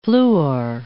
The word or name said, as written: fluor